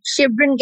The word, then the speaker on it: Chevron: My name is like Chevron Gas Station.